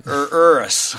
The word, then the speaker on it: Urus: Urus.